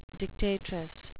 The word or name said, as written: dictatress